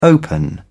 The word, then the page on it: open up: British English